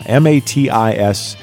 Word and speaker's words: Matisyahu: I believe the name is pronounced Matisyahu, or Matisyahu. Yeah, Matisyahu. I finally stumped you.